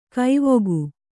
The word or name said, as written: ಕವಿಕೆ